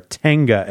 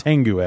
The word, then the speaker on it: Tenga: A lot of people out there correcting you and saying that they are Tenga eggs and not Tengu eggs.